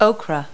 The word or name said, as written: okra